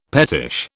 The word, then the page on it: pettish: British English